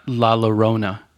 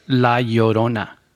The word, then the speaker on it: La Llorona: Okay, so non-Spanish-speaking people, the double L, it translates into a Y sound. So it's not La Llorona, which I've heard a lot of people pronounce. It's La Llorona. – Yeah. – Llorona. You can even say Llorona. La Llorona is correct. It's like Pollo Loco. You don't say Palo Loco. You say Pollo Loco.